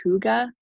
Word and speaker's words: hygge: I think it's pronounced hygge. It's pronounced H-Y-G-G-E.